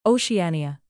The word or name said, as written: oceania